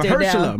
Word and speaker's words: Mahershala: Mahershala. – Now. – Mahershala Ali will be joining us.